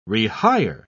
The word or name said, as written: rehire